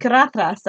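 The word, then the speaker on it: Caradhras: Carathras. That's how you say it.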